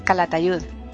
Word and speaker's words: Calatayud: Y hay mucha gente que pronuncia eso con Z. – Sí, lo correcto es eso, es Castillo de Ayud, Calatayud.